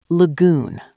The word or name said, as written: lagoon